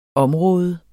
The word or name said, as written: område